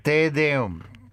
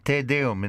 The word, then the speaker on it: Te Deum: And we talked about earlier how some of these Te Deum, how do we pronounce it, guys? The Te Deum? – Te Deum, to God. Te Deum in Latin.